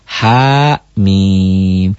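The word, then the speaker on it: Ha mim (حم): Di atas ha itu karena alif ya panjangnya satu alif. Sedangkan di atas mim itu alif panjang, bacanya tiga alif. Satu ya, cara panjangnya. Keduanya dibacanya dieja. Ha mim.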